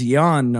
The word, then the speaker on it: yuan: Spending his yuan or whatever, how you pronounce yuan. Chinese yuan. – Yuan. – Okay, yuan.